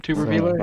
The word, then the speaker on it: Tuberville: I think it's Tuberville, right? Is that how you pronounce his name, Tuberville? – That's right.